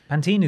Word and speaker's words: Pantene: Pantene is the American pronunciation.